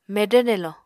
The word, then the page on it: medvr nelo: Apatani